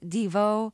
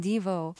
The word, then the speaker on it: Devo: and now I hear you alternately saying Devo and Devo. which is it? – It's both. That's the beauty of Devo. You can't butcher it. It's been pre-butchered.